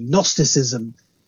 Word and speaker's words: Gnosticism: With a big silent G, Gnosticism.